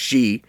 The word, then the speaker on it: Xi: It's not pronounced the same. It's actually pronounced Xi.